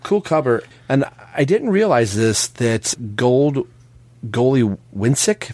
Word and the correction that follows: Godlewski: if I'm saying that right, probably not, is doing the art, but L. Tabe and John Timms are doing the covers for all the rest of the book. So I didn't even remember, was Timms' last book drawing the interiors, was that 15? – It might have been, because Godlewski, I think is how you pronounce it.